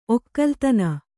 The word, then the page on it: ಒಗ್ಗಾಲಿತನ: oggalitana